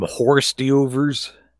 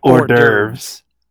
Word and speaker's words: hors d'oeuvres: You want to eat some horse deovers? – Oh, that's, stop that's it. – That's some what? Hors d'oeuvres? – Hors d'oeuvres. – The hors d'oeuvres. – Yeah, we don't pronounce it with the like we have a loogie in your mouth.